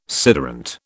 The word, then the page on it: sederunt: British English